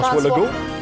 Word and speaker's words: François Legault: François, François Legault.